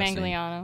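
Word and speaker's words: Manganiello: Joe, I can't say his last Mangliano.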